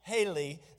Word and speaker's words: Heli: It's actually spelled H-E-L-I. But it is pronounced Haley.